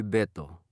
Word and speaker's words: Beto: My name is Alberto, but please call me Beto. – Okay, Beto. And how do you spell your nickname? – It's B-E-T-O.